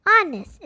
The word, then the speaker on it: honest: Honest is a tricky word to spell. It is spelled H O N E S T. But when you say the word, you don't make a sound. You say honest, not honest.